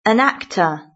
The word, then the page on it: an actor: British English